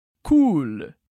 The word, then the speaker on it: cool: «Cool». «Cool». E eu acho que você percebeu que a pronúncia é à la française, um pouco diferente do inglês. Portanto, a gente diz cool.